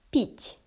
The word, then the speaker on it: пить: Drink in Russian is пить. Пить. Пить. Пить. Say again. Пить.